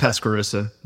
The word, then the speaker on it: Pasquarosa: It's Pasquarosa.